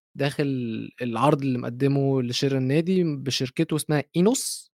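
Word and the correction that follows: Ineos: انيوس مش عارف تنطق ازاي تمام وطبعا آمم، شيخ جمال بن تميمي مظبوط والاسم صح نعم.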